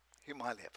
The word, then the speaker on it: Himalaya: But if you want to pronounce it right, Himalaya.